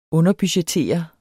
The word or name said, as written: underbudgettere